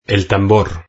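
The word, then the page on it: el tambor: European Spanish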